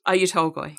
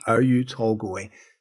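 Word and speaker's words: Oyu Tolgoi: How do you pronounce it? – Oyu-Tolgoi. – Oyu-Tolgoi.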